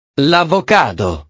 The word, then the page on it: l'avocado: italiano